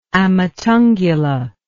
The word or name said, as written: amatungula